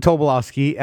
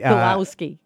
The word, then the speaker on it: Tobolowsky: I keep saying it wrong. – You know, it's Stephen Tobolowsky. – Tobolowsky.